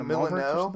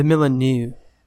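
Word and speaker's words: Molineux: It looks like the Milano. – What's it called? – Milano? – I don't know how you say it, but it's actually the Milanew.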